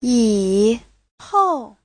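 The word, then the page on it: yihou: yi3hou4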